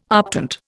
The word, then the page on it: optant: Indian